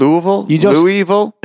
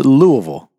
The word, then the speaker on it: Louisville: It's Louisville. – Louisville. – Louisville. – Is it Louisville or Louisville? – It's more like... – Is it Louisville or... – It's more like two syllables.